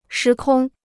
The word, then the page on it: 时空: shí kōng